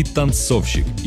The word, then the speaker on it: танцовщик: Ударение в слове «танцовщик» точно так же падает на второй слог, как и в слове «танцовщица».